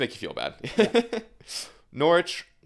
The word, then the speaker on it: Norwich: Norwich. Yeah, thank you. – You cannot pronounce the W in there or they will...